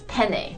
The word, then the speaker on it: penne: It's penne. – Like a pen, if you look at the first three letters, pen that you write with, penne.